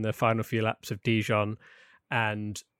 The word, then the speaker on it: Dijon: Is it Dijon? Dijon? – Dijon. – How do you say it? I don't Dijon. – Know. – Dijon. Like the mustard.